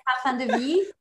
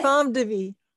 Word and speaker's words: Parfums de Vie: Am I pronouncing it correctly? – Yes, Parfum de Vie. Yes. – Parfum de Vie.